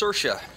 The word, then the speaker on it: Saoirse: It's Saoirse.